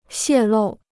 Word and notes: 泄露: xiè lù

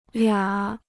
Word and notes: 俩: liǎ

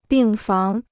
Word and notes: 病房: bìng fáng

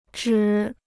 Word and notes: 指: zhǐ

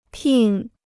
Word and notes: 聘: pìn

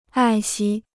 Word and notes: 爱惜: ài xī

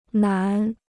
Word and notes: 南: nán

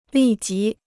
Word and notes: 立即: lì jí

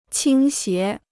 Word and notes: 倾斜: qīng xié

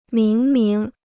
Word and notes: 明明: míng míng

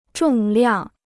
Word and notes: 重量: zhòng liàng

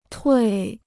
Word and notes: 退: tuì

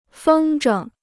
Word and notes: 风筝: fēng zhēng

